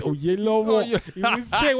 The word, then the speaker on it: Oyelowo: Like, if he's talking to Yoruba people, you say that man is David Oyelowo or whatever. But when he's talking to them, he say it's Oyelowo. When he's talking to his people, he knows how to pronounce his name.